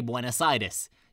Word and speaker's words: Buenos Aires: First of all, that is how you say Buenos Aires.